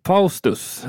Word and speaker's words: Faustus: Faustus, är det så det ska uttalas eller på något annat sätt? – Nej, Nej det, det, är precis så.